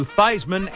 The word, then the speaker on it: Theismann: Joe Theismann, when he was at Notre Dame, his name was Theismann. And the publicity guy at Notre Dame decided to change the pronunciation to Theismann as in Heisman.